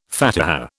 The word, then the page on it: Fatiha: British English